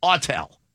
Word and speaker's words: Autel: Will you find me somebody who knows the Autel device? Why do I feel like I'm saying it wrong? Autel. – That's right.